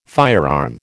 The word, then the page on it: firearm: American English